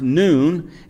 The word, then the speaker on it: Nun: the son of Nun, Moses' assistant, saying, now Joshua, the son of Nun, and literally in the Hebrew, that's pronounced noon,